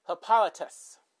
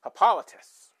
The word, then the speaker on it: Hippolytus: Thank God I watched Wonder Woman, because at least I know how to pronounce that mostly. Hippolytus. You know, like Hippolyta, but, you know, with a T-U-S at the end. Hippolytus.